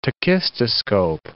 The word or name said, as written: tachistoscope